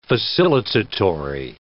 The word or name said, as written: facilitatory